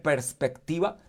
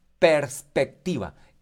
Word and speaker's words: perspectiva: El domingo anterior ya, hice, ya le dije la primer perspectiva. Diga conmigo, ahí donde usted está en su casa, diga conmigo perspectiva. Perspectiva. Er, eh, es P-E-R-S, para que lo pronuncie bien. P-E-R-S, no perspectiva, no. Perspectiva.